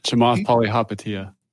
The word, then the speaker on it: Chamath Palihapitiya: How do you pronounce the gentleman's Karmesh? Karmesh? Chamath Palihapitiya.